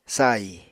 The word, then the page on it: sahi: apatani